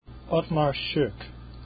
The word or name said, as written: schoeck